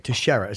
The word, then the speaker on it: Teixeira: T-E-S-H, it looks like. Teixeira. – Teixeira. – Right. – Glover Teixeira.